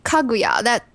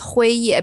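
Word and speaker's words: Hui Ye (辉夜): Yeah, it's very funny because like Hui Ye, that two character, it's in Chinese, it's Kaguya, that two character in Chinese, it's Hui Ye, because those are Chinese character.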